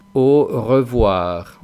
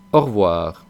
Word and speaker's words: au revoir: Ahí os he puesto que se pronuncia de dos maneras. Depende un poco de si estamos hablando rápido o si hablamos despacio y vocalizamos bien. En este último caso, que es el que vosotros deberíais empezar aprendiendo, es la primera pronunciación que pongo, que es au revoir, au revoir.